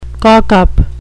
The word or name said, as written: cockup